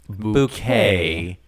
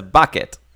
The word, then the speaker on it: Bucket: She says it's pronounced bouquet. The stress is on the second syllable. – Yeah. It says bucket.